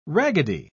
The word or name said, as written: raggedy